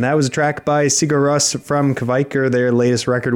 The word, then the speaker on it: Sigur Rós: And closing out this hour, the bottom 10 is Stormur by Sigur Rós off of the new album Kvikr at number 11, up from 17. – Again, I am impressed by your pronunciation.